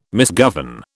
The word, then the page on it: misgovern: British English